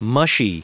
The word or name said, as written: mushy